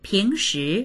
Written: ping2shi2

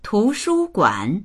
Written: tu2shu1guan3

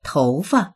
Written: tou2fa

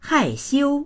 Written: hai4xiu1

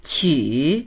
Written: qu3

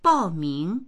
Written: bao4ming2

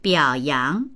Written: biao3yang2

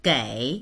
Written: gei3

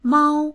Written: mao1

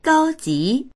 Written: gao1ji2

